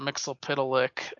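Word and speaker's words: Mxyzptlk: Mixoplick. That's how I pronounce it. – Yeah, that's probably right. I'm trying to think of how they pronounced it on Super Friends. – Yeah, I think they said Mixoplick.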